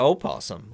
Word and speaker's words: opossum: I still say possum. – Brian still says possum instead of opossum. Like, I'm a biologist, dadgum. – I know. – I still say it wrong.